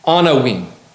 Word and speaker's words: anawim: A Hebrew word that is pronounced anawim. Anawim.